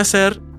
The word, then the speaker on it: hacer: Be aware, however, that because the H sound is silent in Spanish, remember it's hola, not hola, it can be tricky to distinguish the two consecutive A vowels between voya and hacer.